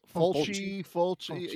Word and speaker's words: Fulci: And what better than the director of Zombie than what, Lucio Fulci? I think that's Fulci. – How you pronounce it. – Fulci.